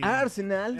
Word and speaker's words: Arsenal: El Arsenal. – El Arsenal. ¿Ustedes sabían que en Inglaterra le dicen en dos sílabas? – ¿Ar?